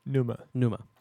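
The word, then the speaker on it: Pneuma: Pneumonia, it's called. Or Numa. – Sorry. Numa. Action Discipline. Recursive slash. – How do you spell that? N-E-U-M-A. – With a P in front. – Yeah. – With a P in front. – W-I-T-H-A-P-E-E-I-N.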